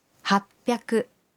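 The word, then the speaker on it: happyaku (はっぴゃく): Y 800 se dice happyaku.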